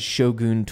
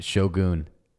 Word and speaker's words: Shogun: We actually had somebody hit us up and say that they were really happy that you pronounce it Shogun and not Shogun. Somebody hit us up on Twitter said, hey, thanks. Shouts to you guys for knowing how to pronounce Shogun, because everybody says Shogun. I even say Shogun. – I'm so cultured. – I'm going to change it now to Shogun because that's the correct pronunciation.